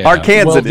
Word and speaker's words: Arkansan: Arkansan is the pronunciation.